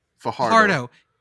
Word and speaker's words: Fajardo: Also, congrats to Cody Fajardo. Is that how you say it? Help me Fajardo.